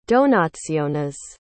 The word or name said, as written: donationes